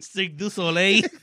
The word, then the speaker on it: Cirque du Soleil: O esconderme la Cirque Sosolil, ¿cómo se llama el cabrón? – Exactamente. – Nunca me sale, cabrón. – Cirque du Soleil.